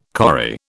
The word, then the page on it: corrie: British English